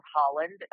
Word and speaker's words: Holland: My name is spelled Holland, H-O-L-L-A-N-D, just like the country. And then my last name is Haas, H-A-I-I, double I's, S, like Sam.